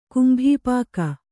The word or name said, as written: ಕುಂಭಿಪಾಕ